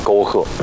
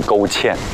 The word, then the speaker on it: 沟壑: 张老师，这个诗词对他们来说可能太难了，刚刚把沟壑读成了沟芡，还笑？